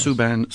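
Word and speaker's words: Suo Gân: Is that correct pronunciation? – That's right. Yes. – Suban, Sleep in Peace.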